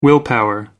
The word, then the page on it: willpower: American English